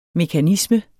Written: mekanisme

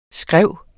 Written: skræv

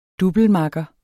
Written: DOUBLEMAKKER

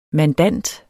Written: mandant

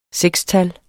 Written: sekstal

